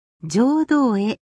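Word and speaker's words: 成道会: 成人式の聖という字に道、そして会議の会と書いて浄土会と読みます。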